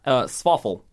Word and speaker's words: swaffle: Swaffle. But it's swaffle. It's waffle with an S at the front. Swaffle. That's all it is basically. – Swaffle.